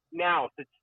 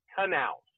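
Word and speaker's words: Knaus: They said, no, you said Nouse. It's Chad Knauss.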